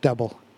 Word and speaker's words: Dobel: That's pronounced double.